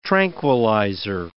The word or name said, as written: tranquilizer